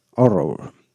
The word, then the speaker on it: Ho99o9: Ellos se llaman HO9909 Pronounced Horror.